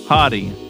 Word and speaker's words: Hadee: How would H-A-D-E-E. How would you say that? – Probably Hadi. – Hadi. Rashid Hadi.